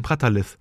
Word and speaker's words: Brattahlid: Das also, du meinst, das H spricht man gar nicht aus, das ist dann quasi stumm. Brattahlid? Hm. Brattahlid. Okay, ja, das klingt doch viel besser.